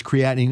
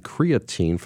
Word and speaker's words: creatine: And so it's really proper sequencing of nutrition, of which creatine is a reasonable tool. – I've been saying creatine for the last 40 years. Is it creatine? – No, it is. You can pronounce it either way.